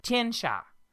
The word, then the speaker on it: Tianxia: Her backstory is that she did live a very long time as a ranger in the plains of Tianxi. How do you say that? Is that right? – I said we call it Tianxia. – Tianxia. She spent a long portion of her life as a ranger guiding people across the plains in Tianxia.